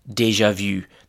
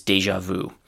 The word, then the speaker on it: déjà vu: The first uh, word from this category is déjà vu. That's actually the French pronunciation, and the pronunciation in English is déjà vu because here we have actually an, an established English pronunciation.